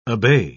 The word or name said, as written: obey